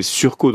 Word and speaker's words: surcot: Non, ce que j'ai vu, c'est surco, donc c'est S-U-R-C-O-T, tout simplement.